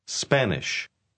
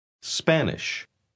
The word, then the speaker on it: Spanish: Spanish. – Spanish. Ponga atención al principio de la palabra. Note que usted debe decir sp y no esp.